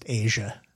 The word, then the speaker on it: Aja: Is there a Steely Dan album called Aha? – Yeah, but that's pronounced Asia.